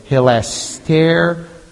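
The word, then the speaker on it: hilasterion (ἱλαστήριον): So hilasterion, that's our word.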